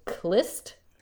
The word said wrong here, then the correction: Khlyst: religious sect. It's Khlyst.